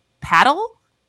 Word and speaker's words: padel: And I think it's pronounced paddle. It's P-A-D-E-L.